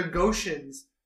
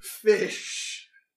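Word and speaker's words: Ghoti: They're not the Goshens. They're pronounced fish.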